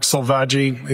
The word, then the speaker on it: Selvaggi: Sure, it's Mark Selvaggi. It's S-E-L-V-A-G-G-I.